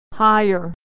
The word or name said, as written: hire